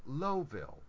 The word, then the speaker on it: Lowville: It's spelled L-O-W-V-I-L-L-E, which for anywhere else in the English-speaking world, it's Lowville. No, no, no, no, no.